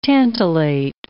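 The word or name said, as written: tantalic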